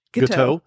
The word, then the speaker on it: Guiteau: Did Charles Julius and Guiteau? – Guiteau.